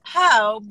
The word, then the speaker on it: Po: It's Poe because people can't say my real name, which is Paola.